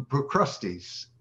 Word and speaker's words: Procrustes: We point at the end to Procrustes.